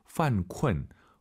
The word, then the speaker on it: 犯困: 犯困, 犯困, 이 발음을 잘 하셔야 됩니다. 쿤쿤 하지 마시고, 유엔 사이에 으어 들어가서 다시 한번, 犯困。